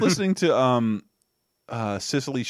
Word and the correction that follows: Cecily: Strong. Am I saying her name right? – Cecily. – Cecily, Strong.